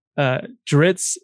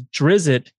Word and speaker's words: Drizzt: On the Robots Radio Discord and on Twitter, he related that something that I didn't know was that Dritz is the preferred pronunciation because Drizzt was how people who like shunned that character and looked down on him.